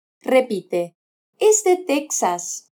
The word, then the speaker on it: Texas: Texas. – Se recomienda escribirlo con X y pronunciarlo con el sonido de J. Jas, Texas.